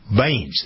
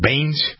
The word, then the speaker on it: Bains: His name is Navdeep Bains. Is that how you pronounce it? Bains? – I think so, yeah.